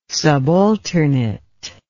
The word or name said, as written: subalternate